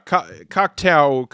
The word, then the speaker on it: Cocteau: I've been listening to some interviews, and that's, yeah, Cocteau is how I have been hearing it pronounced. – Okay, so if we're disagreeing about toe and tau, I feel like we're in good shape.